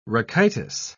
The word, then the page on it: rachitis: British English